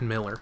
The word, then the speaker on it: Mueller: Miller, Mueller, it's spelt Mueller, but they said Miller, so I'm.